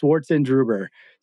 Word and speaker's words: Schwarzendruber: Oh, it's just how it looks. It's always, I know it's a little intimidating, but it's Schwarzendruber. So it's German, means black grapes.